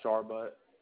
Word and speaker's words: Sharbutt: H-U-N-T-E-R. – Yes, ma'am. – And the last name? – Sharbutt. – You said Sharbutt? – Yeah, Sharbutt. – And spell that for me. – S-H-A-R-B-U-T-T. – Okay. S-H-A-R-B-U-T-T.